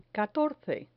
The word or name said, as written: catorce